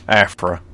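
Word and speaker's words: Aphra: Afra.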